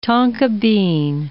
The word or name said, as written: tonka bean